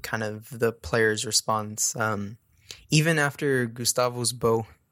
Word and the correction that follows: Bou: I think Bou, according to the league pronunciation site,